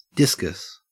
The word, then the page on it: discus: Australian English